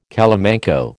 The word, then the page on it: calamanco: American English